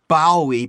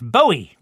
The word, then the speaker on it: Bowie: You're pronouncing his name wrong. It's Bowie, not Bowie. – In England, they pronounce Bowie, Bowie. I thought Canada was part of England. – Well, it's not. And even if it was, David Bowie, Bowie, is not from Canada.